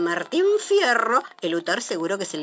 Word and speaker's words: Martín Fierro: ¿Usted sabía que un primero de marzo, pero de 1879, apareció la segunda parte del Martín Hierro? – Martín Fierro, querrá decir, Catita. – Ay, eso lo dirá usted en su ignorancia, Pepe. Pero en buen cayetano se dice hierro, no fierro.